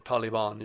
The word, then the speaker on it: Taliban: When the United States did that very tragic rapid pullout, let's say, which created an opportunity for the Taliban, the Taliban is the right way to say that.